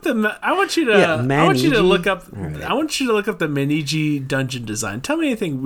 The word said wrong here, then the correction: Manji: M-A-N-I... Oh, sorry, it's just Manji. Sorry. M-A-N-J-I.